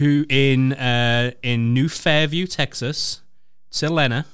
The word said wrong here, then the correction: Lennar: Courtney, how do you say that word? – Lennar.